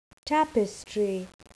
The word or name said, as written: tapestry